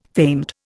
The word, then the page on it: famed: Indian